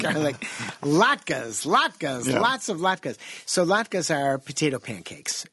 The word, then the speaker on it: latkes: Before our next call, Zorba, garlic and rosemary latkes.